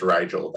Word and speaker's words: Rigel: I think it's pronounced Rigel, though.